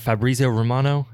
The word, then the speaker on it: Fabrizio Romano: Is it Fabrizio Romano? Is that how you say his name? – Yeah.